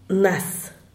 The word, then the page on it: nas: diaz_ordaz